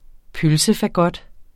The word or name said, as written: pølsefagot